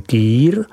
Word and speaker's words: Kir: Víno Aligoté je nenahraditelné i v proslaveném nápoji Kir, vyslovuj Kýr, který své jméno získal po starostovi města Dijonu Felixi Kirovi.